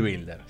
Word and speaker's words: Wilder: Así que podemos decir Billy Builder, ¿sí? – Asumimos que la W se debería pronunciar como B, pero bueno, es tradición y una en Argentina que le digan Wilder.